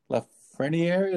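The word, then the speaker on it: Lafrenière: This has some big implications because obviously the draft order and, you know, the main draft pick this year being Alexis Lafreniere.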